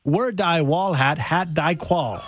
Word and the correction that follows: Wer die Wahl hat, hat die Qual: Okay, not quite. Wer die Wahl hat, hat die Qual.